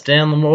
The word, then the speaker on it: Dan LaMorte: Yes, Dan LaMorte.